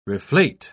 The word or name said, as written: reflate